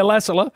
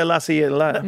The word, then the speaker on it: Lassila: Lydia Lassiella. That's her. How do you say it? – Lydia Lassila. And then... – Lydia Lassila.